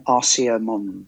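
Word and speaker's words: Arsia Mons: And the ones that you just meant, I can't pronounce it, Arisa Mons or whatever it is. – Valles Marineris and Arcea Mons.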